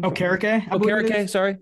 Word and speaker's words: Okereke: Okereke, I think is how you say his last name. – Okereke.